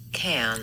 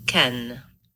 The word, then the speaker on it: Cannes: In English, the name of this French city is Can. Just like a can. Can. In French, it's pronounced ken.